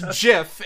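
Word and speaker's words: GIF: It's pronounced Jif, and this has been episode 299 of Talking Heads.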